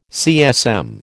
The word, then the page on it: CSM: American English